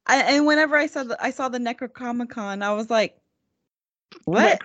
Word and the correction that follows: Necronomicon: Did you just call it a Necrocomicon? – I can't pronounce it. – That is a coin that you should, sorry, that is a term that you should coin. That should be the name of the Evil Dead Comic-Con when it happens. – That would be awesome. Yeah, I know. That's what I always call it because I can never pronounce it right. – Necronomicon. Necronomicon.